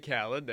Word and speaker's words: Khaled: DJ Khaled.